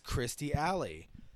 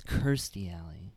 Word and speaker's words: Kirstie Alley: And the person who plays his love interest and his wife is Kirstie Alley. – I think it's Kirstie Alley. – Kirstie Alley. Yep, I knew I was saying it wrong.